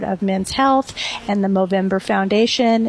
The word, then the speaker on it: Movember: it's called the movember foundation which rhymes with november